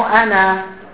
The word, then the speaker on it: أنا: أنا. طبعا هذه الألف لا تلفظ عادة إلا في بعض الأحيان،